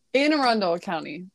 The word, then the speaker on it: Anne Arundel: I think I butchered that. How do you say that? – Anne Arundel County. – Okay. So that everybody who lives around there, that is said correctly.